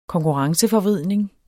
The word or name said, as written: konkurrenceforvridning